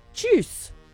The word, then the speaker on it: Tschüss: Like juice with a ch. Tschüss.